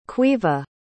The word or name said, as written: Caoimhe